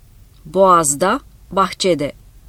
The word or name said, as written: boğazda